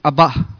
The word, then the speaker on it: Abba: And the last name of God that we will be discussing today is the word Abba. Or the right pronunciation should be Abba.